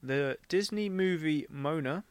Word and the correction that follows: Moana: Is it Mona or Mona? – Moana. – Jeez. I written Mo. I put M-O-A-N-A. – Yeah, that's how it's spelled.